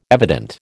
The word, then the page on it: evident: American English